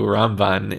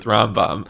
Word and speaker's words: Ramban: And, it's so it's like it's so funny to have to explain who Ramban is because uh, you always get confused with Rambam, Completely. even though even the pronunciation is different. Rambam and Ramban.